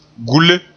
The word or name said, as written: gule